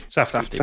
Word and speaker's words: Safdie: the Saft is it Safdie? – Safdie. – Safdie. Yeah.